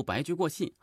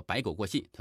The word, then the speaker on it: 白驹过隙: 我举个例子啊，大家可以听那个干音的时候，听着听着发现，嗯，非常快，如白驹过隙。好，那个主播读成了什么白驹？呃，这个白狗过隙，对吧？